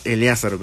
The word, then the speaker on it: Eleázaro: Y si es Eleázaro, con acento en la A.